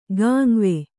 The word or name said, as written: ಗಾಂವೆ